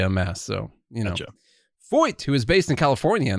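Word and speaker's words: Feucht: I looked up how to pronounce his name. Don't worry, because it is spelled F-E-U-C-H-T. F-E-U-C-H-T. And I decided I should look it up. – Switch. – Yeah. Because I didn't just want to pronounce the letters as they might look.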